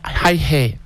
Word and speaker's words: Iha: C'est là qu'il rencontre James Hi-Hay. Alors, je prononce Hi-Hay parce que ça s'écrit I-H-A.